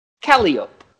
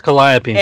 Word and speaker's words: Calliope: The people here say it Calliope. Just like, okay, let me spell a word for you real quick, okay? B-U-R.